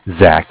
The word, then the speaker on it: Zach: Zach, spelled Z-A-C-H.